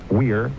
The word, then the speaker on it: vir: In Latin, vir, V-I-R, originally pronounced weir, means man, and so literally, man-wolf, werewolf.